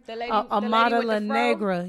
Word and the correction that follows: Amara La Negra: Amara La Negra.